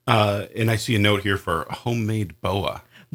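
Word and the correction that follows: bao: Bow. – Oh, okay. – Yeah, so that's actually one of our board members. Wesley makes amazing bow. Sorry, yeah, I said it wrong, too.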